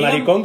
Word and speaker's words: maricón: maricón con acento en la O.